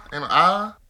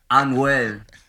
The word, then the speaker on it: Anuel: Is it Ah? Anal Ah? – Anuel.